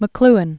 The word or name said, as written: McLuhan